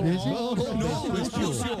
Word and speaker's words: Vespucio: Con ese American Prepucio. – No, Vespucio, Felipe. – ¿Cómo se dice? – Vespucio.